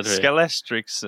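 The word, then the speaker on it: Scalextric: Skalestrix eh, heter det. Om jag ska uttala det korrekt.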